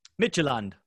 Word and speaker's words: Midtjylland: Midtjylland. – Midtjylland.